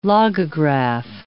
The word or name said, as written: logograph